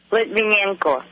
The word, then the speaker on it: Litvinenko: Yeah, help me with my pronunciation here. It's Alexander Litvinenko or Litvinenko? – Litvinenko. – So there'd be kind of a Y in there, Litvinenko.